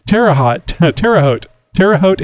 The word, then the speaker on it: Terre Haute: Terre Haute, Indiana.